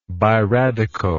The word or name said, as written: biradical